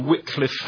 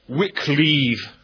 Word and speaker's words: Wycliffe: Normally, one of two spellings is opted for, either W-Y-C-L-I-F or W-Y-C-L-I-F-F-E. Both are well attested, and both are found in the textbooks. It rather looks as if in Wycliffe's own day, his name may have been pronounced Wycleave, because his enemies referred to him as Dr. Wicked Believe. So, obviously, you have to get some kind of rhyme there.